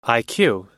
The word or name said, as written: iq